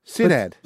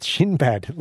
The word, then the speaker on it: Sinad: Senad. S-I-N-A-D. Senad. – But then they ended up with Shinbad.